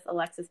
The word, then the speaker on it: Alexys: Facebook, which is just Alexis Berry. But my name is spelled differently. It is A-L-E-X-Y-S, not I-S. And then Berry, B-E-R-R-Y, for Facebook.